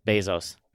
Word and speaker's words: Bezos: Can you imagine if Jeff Bezos was, I said it because you got it in my mind, Bezos. – There you go, you said it properly.